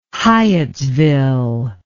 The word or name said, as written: hyattsville